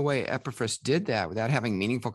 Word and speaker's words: Epaphras: Epaphras.